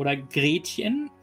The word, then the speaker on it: Gretchen: They start bickering that everything has to be perfect for Gretchen, or Gretchen, how it's correctly pronounced in German.